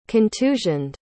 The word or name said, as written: contusioned